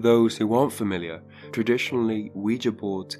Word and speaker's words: Ouija: First up, it's actually thought to be pronounced Ouija, and it's entered popular culture as an otherworldly oracle and something entertaining that's both scary and exciting to use.